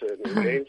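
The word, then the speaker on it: Newgrange: Bueno, lo has dicho fenómeno, es New Grange, y lo has dicho estupendamente. Por ahí nada que objetar. Como bien decía, sí, es la evolución. New Grange es una tumba con pasaje, una de las más fabulosas tumbas con pasaje.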